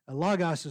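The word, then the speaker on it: Logos (λόγος): Logos is the proper way to pronounce this word because it's omicron, omicron, and not omega, omega.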